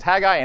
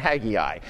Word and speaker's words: Haggai: Haggai, by the way, has two pronunciations, Haggai and Haggai, and they're both perfectly acceptable.